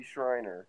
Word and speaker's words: Schreiner: Yeah, he's the minority leader in the House, Casey Schreiner.